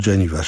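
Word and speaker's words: Janiva: Pisze się tak, jak literuje Janiwa przez V, Janiwa się czyta.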